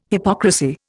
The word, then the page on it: hypocrisy: Indian